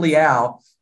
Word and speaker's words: Leal: I guess it's pronounced Liao. I was pronouncing it Leal the entire draft yeah. process, but apparently it's DeMarvin Liao, defensive lineman from Texas Tech.